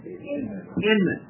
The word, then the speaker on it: Inman: Jennifer Ingham? Inman. Yeah, that's pretty tough. I-N-M-A-N. It's a two-syllable word.